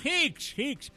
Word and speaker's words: Hicks: Jigs. Jigs. – Ah, Hicks. H-I-C-K-S.